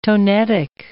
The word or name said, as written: tonetic